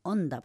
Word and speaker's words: Honda: Lamentablemente es así y con lo que estamos viendo ahora, también están hablando de un cometa, que es el cometa Honda, uh-huh, o Onda, porque nosotros no pronunciamos la H, la H es muda.